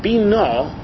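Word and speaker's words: bino (בינה): Rashi says, the word bino, with the accent on the nun, is a noun.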